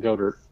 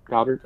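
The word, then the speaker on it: Goedert: And then they've got a couple stud tight ends, which we all know, in Ertz and Goddard. – Goddard. – And I always say that wrong.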